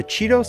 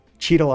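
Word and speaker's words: Cheadle: The Cheeto sculpture and the Hope BC celebration both include weird monuments.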